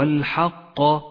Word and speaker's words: الحق: ولاحظ أن حرف القاف في كلمة الحق حرف مفتوح وليس مضموما، فقل هو الحق ولا تقل هو الحق، فإنه لحن جلي.